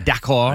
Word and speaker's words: Daccord: You have to pronounce it the French Canadian way. Daccord.